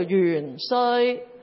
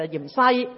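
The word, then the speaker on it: 芫荽: 正確嘅讀音係鹽衰，或者叫元衰，咁不過因為衰字呢就啲人覺得唔係幾好聽，於是就嚴西讀咗咁樣，正音係個衰字嘅。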